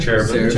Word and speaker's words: Cherubim: Cherubim.